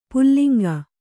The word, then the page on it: ಪುಲ್ಲಿಂಗ: Kannada